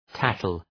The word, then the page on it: tattle: ‘tætəl